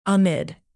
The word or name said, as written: amid